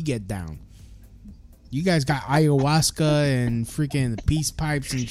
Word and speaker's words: ayahuasca: Ayahuasca. – Ayahuasca.